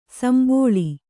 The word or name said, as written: ಸಂಭೋಳಿ